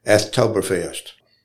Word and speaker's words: Athtoberfest: You know, it's really neat to hear young people say quality of life, parks, green spaces, walkability, events, singing on the square, Athtoberfest. I didn't mispronounce that, y'all. It was A-T-H, Oktoberfest.